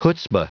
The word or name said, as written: chutzpa